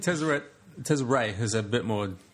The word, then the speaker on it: Tezzeret: He reckons it's too much like is it Tezzeret or Tezzeret? – Tezzeret.